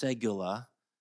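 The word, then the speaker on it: segula (סגולה): It's pronounced segula.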